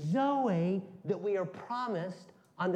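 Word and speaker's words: Zoe (ζωή): Z-O-E with a housetop over the E. It's a long E.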